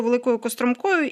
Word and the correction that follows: Великою Костромкою: Так, тільки Великою Костромкою. – Костромкою. Я дякую вам за ці наголоси.